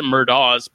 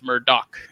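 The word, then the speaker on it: Murdaugh: Like we always pronounce it Murdaws, but apparently it's Murdoch. It's that GH.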